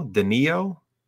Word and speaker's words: Danilo: Ketus Abrigamov taking on, how do you say, is it Danilo? – Danilo, yeah.